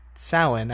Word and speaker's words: Samhain: Uh, uh, you know, he was the one that finds the thing written in blood in the second movie, and he's like Sam Hain, and it's like, wait, this actor's British. He should know that that's pronounced Samhain.